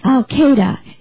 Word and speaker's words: Al-Qaeda: It's pronounced Al-Qaeda. It's pronounced a different way than it's spelled, but maybe not. It's a capital A-L, and then there's a space, and then Q-A-E-D-A.